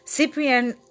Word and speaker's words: Ciprian: You're going to be introduced to Ciprian. Is that how you say your name? – Ciprian. – Ciprian and we met in the Crystals Workshop.